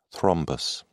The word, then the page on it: thrombus: British English